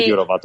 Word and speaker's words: Jourová: No tengo claro cómo se pronuncia, pero Vera Jourová, tú has pronunciado muy bien.